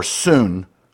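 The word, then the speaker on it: soon (σύν): So one Greek word, which is pronounced soon,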